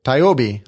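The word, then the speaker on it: TIOBE: And then this next one, I don't know how you pronounce this. Is it Tyobi? – Tyobi.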